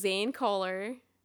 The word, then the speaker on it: Zane Koehler: Is Zane Koehler. I'm saying that right? Yes.